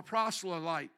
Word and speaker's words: proselyte: He was rejecting his paganism, and yet he had not become a proselyte.